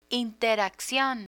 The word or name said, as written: interaccion